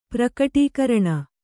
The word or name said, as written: ಪ್ರಕಟೀಕರಣ